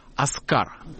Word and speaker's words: Оскар: Только что в Нью-Йорке состоялся фестиваль документального кино, и на нем была показана американская премьера фильма Александра Смолянского и Евгения Цимбала «Оскар». Именно так ударение надо ставить, так ставил это.